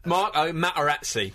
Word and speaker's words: Materazzi: Can we please pronounce the T's in his name? – Marco Materazzi.